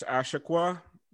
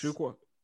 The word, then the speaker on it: Achiuwa: It's Precious Achiuwa. – Yes. Achiuwa.